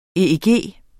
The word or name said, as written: EEG